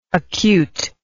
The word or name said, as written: acuteness